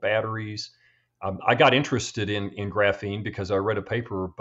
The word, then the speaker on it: graphene: I've always called it graphene, but I note that you pronounce it graphene, emphasis on the second syllable.